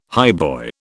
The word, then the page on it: highboy: British English